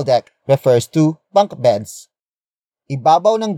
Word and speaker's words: ibabaw: It is the focus marker that introduces the main topic of this sentence, which in this case is a phrase starting with ibabaw. Ibabaw. Ibabaw. Ibabaw is spelled as I-B-A-B-A-W.